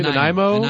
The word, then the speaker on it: Nanaimo: Joining us down the line from Nanaimo, British Columbia. Did I say that correctly? – Nanaimo.